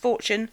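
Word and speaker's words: Fourtune: There was a band called Fortune, spelt four tune, like the number four, T-U-N-E, but pronounced Fortune.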